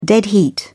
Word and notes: dead heat: British English

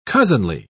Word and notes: cousinly: British English

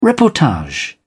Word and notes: reportage: British English

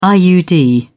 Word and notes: iud: British English

